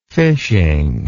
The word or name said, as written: fishing